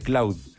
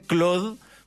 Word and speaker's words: Claude: En teoría debería pronunciarse Claude, porque Cloud es nube y se confunde con la palabra nube además, entonces que es una palabra muy usada en esta industria.